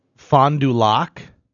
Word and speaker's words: Fond du Lac: Fond du Lac.